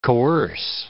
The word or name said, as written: coerce